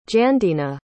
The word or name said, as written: Jandina